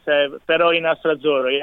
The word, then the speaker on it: Peroni Nastro Azzurro: So you're involved in the House of Peroni Nastro Azzurro event that's happening? Did I say that right? I hope I did. – Yeah, that's perfect, yes. Peroni Nastro Azzurro, yeah, correct, yeah.